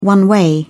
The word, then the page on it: one way: British English